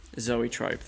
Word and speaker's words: Zoetrope: Zoetrope, thank you.